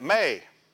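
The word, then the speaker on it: may (μή): Transliteration would be M-E, this is mu and the eta, but really you'd pronounce that may.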